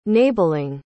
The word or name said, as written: nabelung